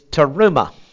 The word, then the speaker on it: terumah: Now, the word for heave offering is terumah. T, the short E, R-U-M-A-H, terumah.